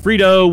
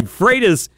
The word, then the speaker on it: Freitas: Technically, it's pronounced Freitas, but my drill sergeant taught me to respond to anything close. – all right. Freitas is the right way to say it.